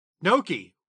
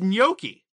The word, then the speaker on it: gnocchi: So, the way I pronounce gnocchi, the way I always heard it, is that you have that Y sound in it.